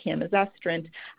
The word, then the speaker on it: camizestrant: So the first one I wanted to talk about is a drug called camizestrant, and that's spelled C-A-M-I-Z-E-S-T-R-A-N-T, camizestrant.